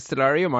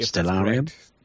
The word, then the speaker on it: Stellarium: I happen to have the application on my Mac called, is it Stellarium? – I pronounce it Stellarium. I have no idea. – Stellarium? – Yeah. – Let's go with that.